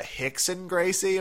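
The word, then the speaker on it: Rickson Gracie: Rhymes with Dixon. Hickson Gracie, am I getting that correct? – You are.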